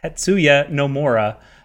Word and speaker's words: Tetsuya Nomura: This is where the Tetsunomura, I mispronounced his name twice, Tetsuya Nomura, just came off of Kingdom Hearts 3 to jump in and work on FF7 Remake.